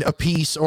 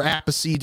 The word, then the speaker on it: Appice: The Beck, Jeff Beck, Tim Bogart, Carmine Appice, or Appice, depending on how you'd like to pronounce it.